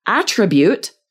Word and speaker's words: attribute: And then for attribute, the noun where we're stressing that first syllable, this would be so great for speaking if, like on part 2, you're asked to describe a friend.